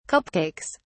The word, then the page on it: cupcakes: British English